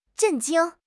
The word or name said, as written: 震惊